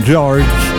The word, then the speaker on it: Giorge: Questo il singolo di George Pettus, scritto però G-I-O-R-G-E, quindi in maniera un po' particolare, comunque si pronuncia George, evidentemente.